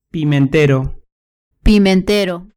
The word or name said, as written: pimentero